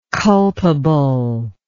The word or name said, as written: culpably